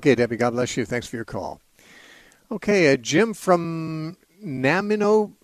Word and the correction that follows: Nanaimo: British Columbia. How do you say that? – It's Nanaimo. – Okay, yeah. It was even spelled wrong for me here, but yeah, Nanaimo.